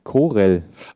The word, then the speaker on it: Corel: So, dann bekommt man für 22,50 Euro die Coral Paint Shop Pro Photo X2 Licensing Media Pack. CD. – Moment mal, ist das Gore ausgesprochen oder Gore wirklich? – Corel.